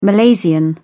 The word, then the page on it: malaysian: British English